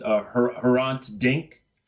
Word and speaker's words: Hrant Dink: And you begin the book by talking about someone named, if I'm pronouncing the name correct, Hrant Dink. – Yes.